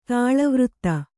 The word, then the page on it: ತೈಲವರ್ತಿ: Kannada